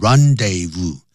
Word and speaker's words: rendezvous: The Moulin Rouge was the rendezvous, 就念成 rendezvous, du high life.